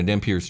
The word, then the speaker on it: Dhampir: Welcome to the Dungeon Cast, where we pronounce it Demogorgon and Dhampir. – Dhampir. – Yeah, indeed. – This is just a fact.